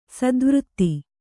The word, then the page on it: ಸ್ಥಾವರತೆ: sthāvarate